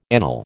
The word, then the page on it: anele: American English